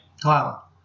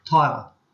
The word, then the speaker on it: Tyler: Tyler. – Sorry? – Tyler. – How do you spell that? – T-Y. – T-Y. – T-Y-L-E-R.